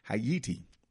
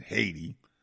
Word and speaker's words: Haiti: She is doing the work in Haiti. I know y'all pronounce it Haiti, but it's pronounced Haiti.